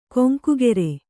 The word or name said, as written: ಕೊಂಕುಗೆರೆ